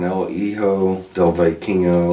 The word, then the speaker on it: El Hijo del Vikingo: They had Triple Mania's over, and El Hijo del Vikingo, or whatever you pronounce that. – I think you got it.